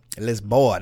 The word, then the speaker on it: Lisboa: Lisboa, that's how they say it.